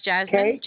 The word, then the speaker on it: Jasmin: Deluxe is spelled D-E-L-U-X-E. My name is Jasmine, J-A-S-M-I-N.